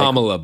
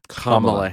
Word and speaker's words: Kamala: Kamala, by the way. – Sorry. Kamala Harris. – It's funny. All Republicans say her name wrong.